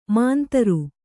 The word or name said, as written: ಮಾಂತರು